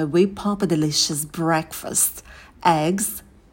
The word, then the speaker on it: eggs: Eggs. Когда произносим «э», то держим оскал. В конце звук «з» будет звонкий, не оглушайте.